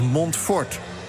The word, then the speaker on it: Montfort: Ik werd daar terecht door gecorrigeerd in de NPO Radio 1. Het is Montfort. – Oh, klemtoontechnisch gesproken. – Montfort, ja. Ik heb Montfort. Wist u dat, meneer, dat het Montfort is en niet Montfort?